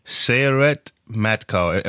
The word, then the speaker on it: Sayeret Matkal: Okay, so the name of the unit is Sayeret Matkal. Am I pronouncing that correctly? – Yeah, this is the name.